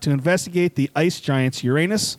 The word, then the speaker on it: Uranus: NASA wants your help studying Uranus from behind next month. – It's actually pronounced Uranus.